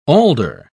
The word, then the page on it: alder: British English